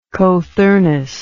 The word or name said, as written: cothurnus